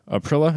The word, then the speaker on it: Aprilia: which would include brands like Aprilia.